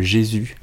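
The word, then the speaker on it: Gesù: Et j'ai un coup de cœur, c'est pour euh, une salle qui s'appelle le Jésus. On s'écrit G-E-S-U.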